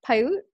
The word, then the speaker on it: Paiute: So, you grew up in a reservation, and I believe you're a descendant of the, and you can correct me if I'm saying this wrong, the Northern Paiute, is that correct? – Yeah.